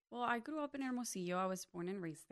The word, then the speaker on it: Hermosillo: Hermosillo. Mm-hmm. Am I pronouncing it correctly? – Hermosillo, yes.